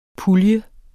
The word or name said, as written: pulje